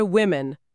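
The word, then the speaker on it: women: وایسید، مورد وومن هم هست که جمعش میشه ویمن. این خیلی عجیبه چون نه تنها نوشتارش بلکه تلفظش هم از وومن به ویمن تغییر میکنه.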